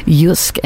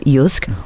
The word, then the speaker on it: JYSK: Now, this is the company behind Yusk, and we have had a number of people texting in to ask how you pronounce, how you spell Yusk. Obviously, it is J-Y-S-K.